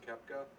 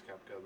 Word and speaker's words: Koepke: I think it's, I want to say it's pronounced Koepke. – Might be. – It's K-O-E-P-K-E, or, yeah, P-K-E. K-O-E-P-K-E. – Yeah, I want to say it's Koepke.